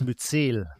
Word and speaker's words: Myzel: Ich lese einmal vor, ähm, Myzel, sagt man das, spricht man das so aus beim Pilz? Myzel. Myzel, ah, Entschuldigung. Myzel-Netzwerken wird eine erstaunliche Intelligenz unterstellt, mit der sie Informationen über ihre Umgebung einholen und weitergeben.